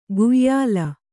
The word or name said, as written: ಕುಯ್ಯಲ್